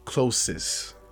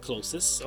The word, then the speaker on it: Closys: They got a particular oral rinse that I use now that's non-alcoholic called Closest. – Closest? – You ever heard of that? Yeah, I think I'm saying it right. C-L-O-S-Y-S. – Closest, okay.